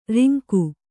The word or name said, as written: ರಂಗ